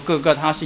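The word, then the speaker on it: 各各他: 在圣经里说各个它是亚兰文的发音，圣经里特别解释说它就各个它的意思就是独楼地哈，因为它的那个形状，那个下面的这个形状，这个可能看得不太清楚，看起来有点像骷髅的意思哈。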